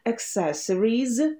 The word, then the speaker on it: accessories: Весьма каверзное слово. Accessories произносим. Accessory – аксессуар. Accessory – accessory. Accessories. Accessories. Accessories. Второй слог ударный. И comfortable. Там у нас comfort. А-а-а.